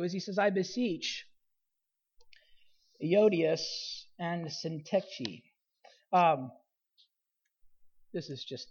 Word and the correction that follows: Euodia: So here's a pronunciation. I'm doing my best. Euodia.